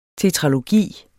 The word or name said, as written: tetralogi